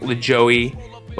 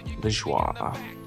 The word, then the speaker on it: Lajoie: But check out this music video for a little taste of John Lajoie, and that is the right way to say it. That's why I've said it so many times. I know it looks like LaJoey, but it's Lajoie.